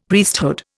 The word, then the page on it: Priesthood: Indian